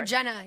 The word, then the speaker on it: Jenna: Tatum and Gina, or how do you say her name? – Gina Dewan. – Yeah. Divorce. – Oh, Jenna. Yeah, yeah, it's Jenna. Jenna and it's Channing.